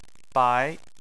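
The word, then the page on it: bai: tone 2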